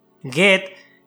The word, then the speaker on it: Goethe: Goethe,